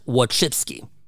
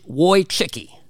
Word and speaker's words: Wojcicki: Her name is Susan, I believe it's pronounced Wojcicki. Wojcicki, yes. Former YouTube CEO, her son passed away.